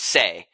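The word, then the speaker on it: sei (性): And then there's a kanji, which this pronunciation is sei, which literally means the nature of things.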